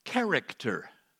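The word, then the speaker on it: character (χαρακτήρ): That word in the Greek, the exact representation, the Greek word is the word character. That's exactly how it's pronounced in Greek.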